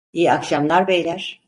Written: beyler

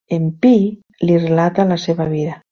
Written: relata

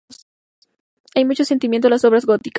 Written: sentimiento

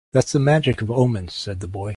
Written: omens

